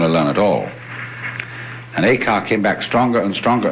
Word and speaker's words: Eka: Eka is Japanese pronunciation, like Zen is the Japanese pronunciation of the Chinese Chan.